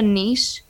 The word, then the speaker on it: niche: So a niche is like... So an example of a niche, or like the Americans sometimes say niche. I feel like you guys say it right.